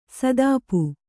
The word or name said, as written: ಸುತಪ